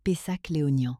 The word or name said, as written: Fermentation